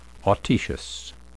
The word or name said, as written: autoecious